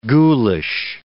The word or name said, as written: ghoulish